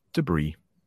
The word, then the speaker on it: debris: I think it comes from the French, but it's also pretty hard to pronounce because you see that S at the end of the word, and you might want to pronounce it, but we don't. Debris.